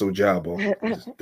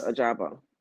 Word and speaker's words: Ojabo: It's Ojabo. David Ojabo. – Okay, that's good. Okay, that's good. Ojabo. – Sound just like it looks.